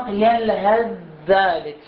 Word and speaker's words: يلهث ذلك: الآن ينطق بهذا الشكل، يلهث ذلك ينطق يلهذ ذلك يلهذ ذلك يلهذ ذلك أحسنت، لأنه الحرف المتكون المشدد يكون مجهور، لأنه شنو صفة الحرف الثاني؟